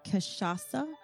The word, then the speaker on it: cachaça: But this is the frozen caipirinha, and the spirit in it is pronounced cachaça.